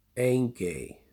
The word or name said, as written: Engey